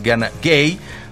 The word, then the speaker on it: Gana Gueye: Lamine Camara, porque también tiene su Lamine allá en Senegal, está en la media cancha junto con Gana Gueye, quiero decir, la pronunciación es Gana Gueye.